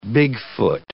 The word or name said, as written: Bigfoot